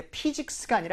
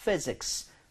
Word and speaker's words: physics: 이거 발음할 때 physics가 아니라 physics, physics. So 입술이 붙으면 안 돼요. 아랫입술 위에 윗니 살짝 올리고 바람이 나와야 됩니다.